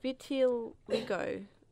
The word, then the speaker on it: vitiligo: It's called vitiligo. V-I-T-I-L-I-G-O.